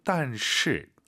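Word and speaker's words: 但是: 혹은 두 글자로 딴시.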